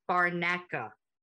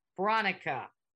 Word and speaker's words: Branca: It is spelled B-R-A-N-C-A. Barnacka. We're gonna go Barnacka. Barnacka. – Like Bronco, but okay.